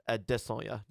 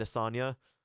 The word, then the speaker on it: Adesanya: Yes, that is pronounced Adesanya, right. – Not Adesanya. It's kind of a thing. It's Adesanya.